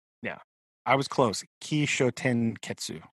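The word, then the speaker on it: Kishōtenketsu: Kisho. And the O has like a line over it if you want to go for the long vowel sound. Kishoten Ketsu.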